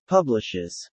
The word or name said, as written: publishes